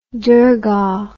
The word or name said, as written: dargah